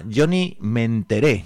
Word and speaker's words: Johnny Me Enteré: El grupo se llamaba Johnny Me Enteré, con acento en la E.